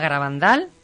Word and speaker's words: Garabandal: El Enigma Garabandal, lo he dicho bien, ¿verdad? Bien.